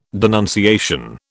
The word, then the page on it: denunciation: British English